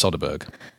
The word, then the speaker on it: Söderberg: I've had a look at the Swedish pronunciation guidelines, and I believe it's Söderberg.